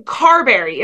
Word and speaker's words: Carberry: Currently lives in Carberry.